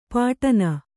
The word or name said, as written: ಪಾಟನ